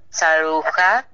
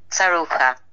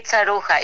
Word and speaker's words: Tsaroucha: Okay, in Greek we say Maria Olon Tsaroucha. And in America, we say Maria Olon Tsaroucha. We should say Tsaroucha. It's just very difficult for people here to pronounce that name, so that's why I have also Olon.